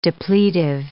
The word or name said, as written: depletive